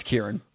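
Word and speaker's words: Ciarán: It's spelled C-I-A-R, A with a little weird thing. – I hate the weird thing. – And N. So it's Ciaran. Let's go with Ciaran. – Ciaran. – It's Kieran.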